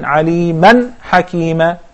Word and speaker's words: عَلِيمًا: And that's why we pronounce the تنوين clearly by saying عَلِيمًا حَكِيمًا. You could hear the sound of the noon.